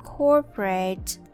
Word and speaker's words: corporate: COR pronounced as core, core, and the OMPO pronounced as a. Uh, corpa, corporate, corporate.